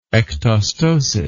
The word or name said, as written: ectostosis